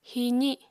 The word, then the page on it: hiizi: Apatani